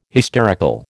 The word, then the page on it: hysterical: American English